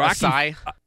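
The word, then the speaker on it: açaí: The Aki bowl? Or Acai. – Is that how it's pronounced? – I think so.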